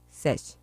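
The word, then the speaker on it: Sech: ¿Es Sesh o es Sek? – Sesh.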